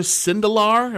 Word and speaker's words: Sindelar: You tweeted something out about the quarterback, Elijah Sindelar. Am I saying that right? – You are,